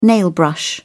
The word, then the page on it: nailbrush: British English